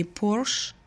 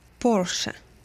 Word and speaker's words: Porsche: Это не Porsche, это не Porsche, а Porsche.